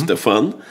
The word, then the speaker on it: Стефан: Был такой человек, местоблюститель патриаршего престола, Стефан Яворский, причем вот в данном случае совершенно точно ударение на последнем слоге, да. Стефан.